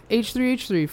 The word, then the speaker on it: H3H3: Yeah, H3H3.